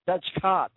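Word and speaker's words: Dutchcot: It's Cott. Dutchcott. Get your minds out of the gutter. Dutchcott. Just wanted me to say it again, didn't you? Oh, the Balance crew, these guys rock, let me tell you. Never miss a point. District Judge Leslie Dutchcott. That's how you say it.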